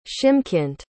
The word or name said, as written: shymkent